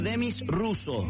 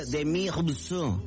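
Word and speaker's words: Demis Roussos: Una señora me dice, se llama Demis Russo. No. – Rusia. – Rusos, se escribe Rusos con dos S, pero se pronuncia Demi Russo.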